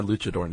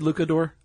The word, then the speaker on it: luchador: That's my luchador name. – Okay. And you pronounced it correctly. It's not Luke. It's Luch. – Oh, do people say Lucador? – I did in my head.